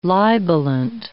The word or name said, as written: libellant